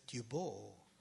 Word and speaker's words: Dubois: And Charles Dubois, I asked our French speakers, how do you say Dubois? And then she said, Dubois.